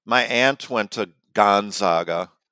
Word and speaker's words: Gonzaga: I think it's pronounced Gonzaga.